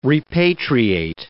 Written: repatriate

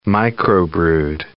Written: microbrewed

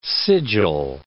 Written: sigil

